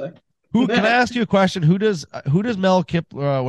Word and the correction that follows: Kiper: Kiper. – I knew I was going to say that wrong.